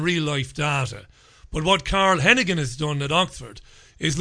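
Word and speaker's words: Heneghan: I think they pronounce it Hennigan. In Ireland, we pronounce it Heenahan, of course.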